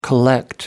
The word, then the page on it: collect: American English